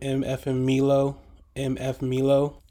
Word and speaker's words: MF Milo: There's this dude named MF Milo is exactly how it sounds.